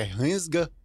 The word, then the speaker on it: Rensga: Para o mês de agosto, que é Hansga Hits, falei certo? Falou.